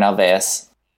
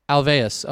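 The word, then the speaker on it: Ulvaeus: Bjorn Alvaeus. – Alvaeus.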